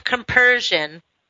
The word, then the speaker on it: compersion: is this idea of compersion. Did I say it right? – Yes.